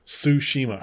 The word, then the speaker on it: Tsushima: Am I saying that right? – Tsushima. – Tsushima. – Like sushi, but Tsushima.